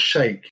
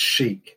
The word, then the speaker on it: Chic: But it's not Shake, Richard. I must correct you. It's Chic. – It's got to be Chic. – It has to rhyme with Mozambique.